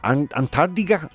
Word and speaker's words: Antártida: How do you say Antarctica? Antarctida.